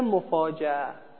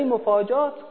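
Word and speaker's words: مفاجئه: اینم مفاجعه، مفاجعه. اما اگه بخواید با همزه دقیق تلفظ کنید میشه مفاجعه، اما مفاجعه یعنی یه حالت بین، یعنی تیزی همزه رو ازش میگیری، خلاصش اینه، تیزی همزه رو ازش میگیری. ولی مفاجات غلطه، مفاجات غلطه.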